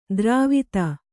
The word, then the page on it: ದ್ರಾವಿತ: drāvita